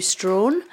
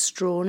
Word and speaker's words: Strachan: It's spelt Strachan, S-T-R-A-C-H-A-N, but it's definitely pronounced Strawn. It's a Scottish surname. My father was always very insistent that we pronounced it Strawn, and it's basically because our family are from north of the Tay.